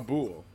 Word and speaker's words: boule: A boule. Like, a boule? – A boule, yeah. – It's a French word for ball. – Boule. – Interesting. – Boule. C'est boule. It is a boule.